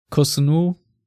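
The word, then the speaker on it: Kossounou: How do you pronounce him? Kosunu? – Yep.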